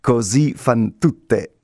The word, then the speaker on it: Così fan tutte: Così Fan Tutte. – And what is that? – Così fan tutte is an opera by Mozart.